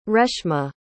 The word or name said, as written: reshma